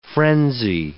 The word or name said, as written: frenzy